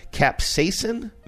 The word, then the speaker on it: capsaicin: Capsaicin, maybe, is a chemical compound.